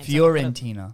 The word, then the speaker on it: Fiorentina: Like, even our targets, the Florentina striker, I can't pronounce his name. – Fiorentina. So Florentina. – Fiorentina. – Fiorentina.